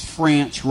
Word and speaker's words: French: Kind of like Martavius, former Vaughn Martavius, French rhymes with ranch.